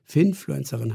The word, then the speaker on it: Finfluencerin: ich kann das Wort nicht mal aussprechen, Finfluencerin heißt das, glaube ich, ganz genau.